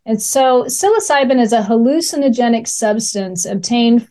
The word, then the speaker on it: psilocybin: So talking about, and please help me because I Googled this even and hit pronounce, psilocybin. Am I pronouncing that correctly? – That's correct. Yes.